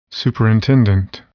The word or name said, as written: superintendent